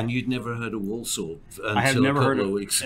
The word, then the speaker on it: Walsall: Walsall, is that how you pronounce it? – Walsall.